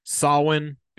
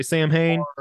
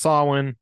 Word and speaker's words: Samhain: So everything that he does, whether it's Danzig, whether it's Samhain, because that's how it's pronounced. A lot of people say Samhain. It's pronounced Samhain.